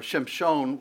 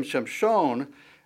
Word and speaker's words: Shimshon: or Shimshon would probably be the way that you pronounce it, which actually means like the sun, like the sun.